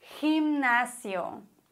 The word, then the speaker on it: gimnasio: Was ist, wenn das G direkt vor E oder vor I steht? Dann müssen wir es wie ein H oder wie ein Gente, wie ein CH eigentlich aussprechen. La gente. Okay? Gente. Oder GI wäre zum Beispiel Gimnasio. Da schwingt ein bisschen ein CH mit.